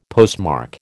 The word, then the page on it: postmark: American English